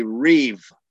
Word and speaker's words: riv: And the covenant lawsuit is a riv, an R-I-V. That's how you would, it's really written R-I-B in Hebrew, riv. But the B, the letter bet, oftentimes is pronounced like a V instead of a B.